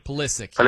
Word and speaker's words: Pulisic: Hopefully I said his name right. Pulisic. – That's the name, right? – Pulisic. Yeah. Yeah.